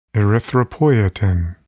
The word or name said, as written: erythropoietin